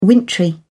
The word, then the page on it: wintry: British English